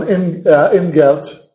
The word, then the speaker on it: Ingeld: Bueno, se casa con Ingeld, deletreado con D, pero pronunciado con T,